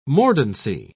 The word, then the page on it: mordancy: British English